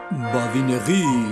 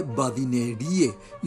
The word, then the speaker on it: badinerie: Badinegui. Badinegui se escribe Badinerie y solemos pronunciar en castellanizado Badineri en español.